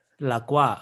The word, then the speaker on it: La Croix: How do you pronounce the carbonated, like slightly barely flavored soda, like that is two words that are in French? – Oh, La Croix? – La Croix. Okay, you say that.